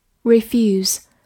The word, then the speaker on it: refuse: refuse, r e f u s e, refuse, r e 发 r e f u 发 f u few s e 读作浊辅音 z，所以 refuse 它是一个动词，表示拒绝或者回绝。